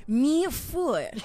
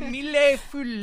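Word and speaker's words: mille-feuille: Okay, this one is a mi-four. – A mille-foule. – No, it's French, so you don't say the L's.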